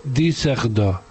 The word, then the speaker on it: Düsseldorf: Es que Düsseldorf lleva la Düsseldorf que se escribe, lleva dos puntos en la U. Y entonces se dice Düsseldorf.